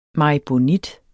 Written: maribonit

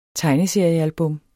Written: tegneseriealbum

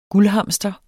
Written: guldhamster

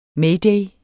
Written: mayday

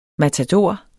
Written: MATADORERNES

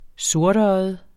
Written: sortøjet

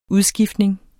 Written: udskiftning

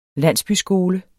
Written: landsbyskole

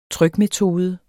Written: trykmetode

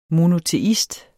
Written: MONOTEIST

